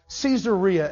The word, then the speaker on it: Caesarea: He wanted to sort of kiss up to to Caesar Augustus, and so he named a city after him, Caesarea, or Caesarea, as you might pronounce it.